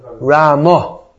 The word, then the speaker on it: Ramo: Ramo. Is Bengali or Oriya pronunciation? – It's is it? – Yeah, like in vocative, we call it Ramo.